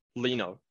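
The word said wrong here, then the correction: Iino: I think Eno. It's actually it's I, double I. Nana C. Eno.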